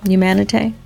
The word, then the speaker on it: Humanité: You know, so going back to Humanité, did I say it correctly? Humanité? – Parfait.